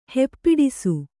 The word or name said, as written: ಹೆಪ್ಪಿಡಿಸು